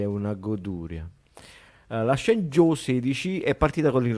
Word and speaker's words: Shenzhou: Parlavamo anche della capsula Shenzhou 16 mm, perché porta tante novità. – È una aspetta, aspetta, facciamolo pronunciare alla vero. – Vai. – Shenzhou.